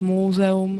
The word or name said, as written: múzeum